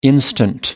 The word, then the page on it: instant: American English